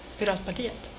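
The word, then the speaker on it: Piratpartiet: Vamos a hablar del Partido Pirata, que en sueco es el Piratat Partiet, o algo así, ¿no? ¿Qué tal? Muy buenas. – Pirat Partiet. – ¿Ah? Pirat Partiet. – Pirat Partiet. – Perfecto, fantástico, en esa excelente pronunciación sueca de nuestra invitada. Good afternoon.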